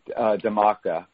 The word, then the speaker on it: Dhamaka: Yeah, Damaka. It's spelled D-H-A-M-A-K-A. And we have a client. Yeah, we have clients here called Aurum, which is A-U-R-U-M, which is golden in Indian.